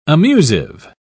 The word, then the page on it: amusive: British English